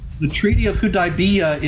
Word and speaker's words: Hudaybiyah: Hudaybiyah. – Yes, Hudaybiyah.